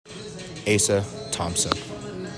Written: ASA THOMPSON

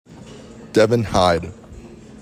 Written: DEVIN HYDE

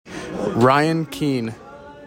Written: RYAN KEAN

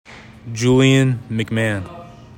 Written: JULIAN MCMAHAN

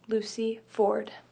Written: Lucy Ford

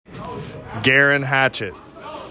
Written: GEIREAN HATCHETT

